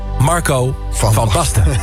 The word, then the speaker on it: Marco van Basten: Ik herhaal, Marco van Basten. Want vorige week, toen ik de naam van Marco van Basten wilde uitspreken, ging het niet echt lekker.